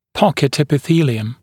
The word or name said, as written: pocket epithelium PE